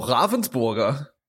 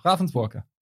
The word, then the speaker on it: Ravensburger: Oh, Ravensburger. – Yeah, Ravensburger. – Nicely pronounced.